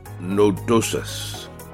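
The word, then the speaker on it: Dichelobacter nodosus: What sheep disease is caused by the bacterium Dilobacter nodosus? Okay, I'll spell that. D-I-C-H-E-L-O-B-A-C-T-E-R. Second word, nodus. N-O-D-O-S-U-S.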